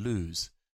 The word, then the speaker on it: lose: So loose and lose, different pronunciation. – そうそうそう。このスペルは。 – To lose something is L-O-S-E. – L-O-S-E でしょ ?Only one O.